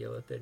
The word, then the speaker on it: illithid: Yes, the illithid.